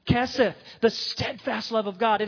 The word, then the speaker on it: hesed: That word in Hebrew is H-E-S-E-D, pronounced keseth.